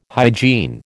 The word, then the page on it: hygiene: American English